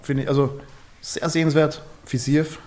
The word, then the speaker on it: Fiziev: Fiziev, gut ausgesprochen, mein Freund.